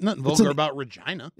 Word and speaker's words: Regina: The capital city is Regina. – Is what? – Regina. You know, I just want to get back up on that pogo stick. Regina. – Mm-hmm. Regina. – It rhymes with vagina.